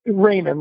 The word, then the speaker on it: Rehmann: Yeah, Rehmann, like everybody loves Raymond, the television show.